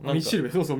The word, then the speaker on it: 金字塔: ミチルベ、そうそうミチルベって読んでる、うん、え金字塔って書いてミチルベって読んでる、